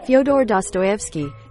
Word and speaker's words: Fyodor Dostoevsky: Fyodor Dostoevsky. – Yeah, that person wrote one of his most famous books, The Gambler, because he needed quick cash to pay off gambling debts. Oh, Fyodor Dostoevsky.